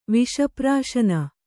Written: ವಿಷಪ್ರಾಶನ

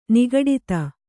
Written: ನಿಗಡಿತ

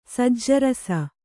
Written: ಸಜ್ಜರಸ

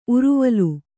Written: ಉರುವೇಲು

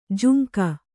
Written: ಜಾಂಗು

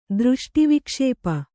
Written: ದೃಷ್ಟಿವಿಕ್ಷೇಪ

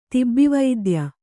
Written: ತಿಬ್ಬಿವೈದ್ಯ

